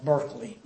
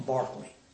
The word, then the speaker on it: Berkeley: His name looks like Berkeley, but it's pronounced Berkeley.